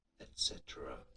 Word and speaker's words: et cetera: Now, if you just looked at the word issues, et cetera, you see that it's ETC, not ECT, but it is a very common mispronunciation, and even the most intelligent, well-read people occasionally do it. – Et cetera. – Et cetera. Et cetera. – That's how you say it.